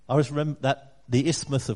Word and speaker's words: isthmus: I've got a question for you, and the question is, what is an isthmus? Did I say that right? Neck? Yep.